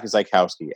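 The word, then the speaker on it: Zyskowski: So it's at Zach Zykowski, at ZACHZYSKOWSKI.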